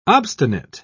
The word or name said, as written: obstinate